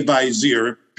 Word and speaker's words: Vizier: People ask, what's the official way to pronounce this? Stan Lee would always say, there's no official way. It's a fictional character, and what they want to know is, how do you as the creator pronounce it? He was like, I might not even be pronouncing it right, but I say Vizier.